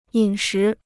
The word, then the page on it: 饮食: yǐn shí